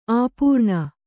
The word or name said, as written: ಅಪರ್ಣ